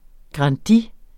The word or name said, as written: grandi